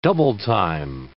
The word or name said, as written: double-time